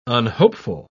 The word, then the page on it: unhopeful: British English